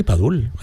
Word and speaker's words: Padul: Tengo también Padul. Padul, sí, no sé si lo digo bien, perdonadme. – Sí, sí, Padul, Padul, Granada.